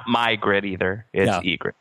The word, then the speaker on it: Ygritte: And thank you, Matthew, for now explaining to me that the Y in Ygritte is silent. Silent Y at the start of a name.